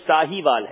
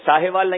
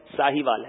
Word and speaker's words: ساہیوال: پانچواں سوال ہے، مجھے بتایا گیا تلفظ درست کریں، یہ ساہیوال ہے، ایسے ہی ہے، ساہیوال نہیں ہے بھائی، ساہیوال ہے.